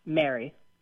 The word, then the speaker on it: merry: And so let me ask you how you pronounce the word M-A-R-R-Y. – Mary. – And how do you pronounce the word M-E-R-R-Y? – Mary. – And do those sound the same to you? – I guess Mary with the E sounds maybe a little bit shorter.